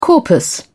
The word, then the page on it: corpus: British English